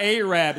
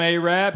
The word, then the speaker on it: Arab: If you tell about Arab, it's spelled A-R-A-B. They don't pronounce it Arab. They pronounce it Arab, because the original name was Arad, but they misspelled it on the water tower.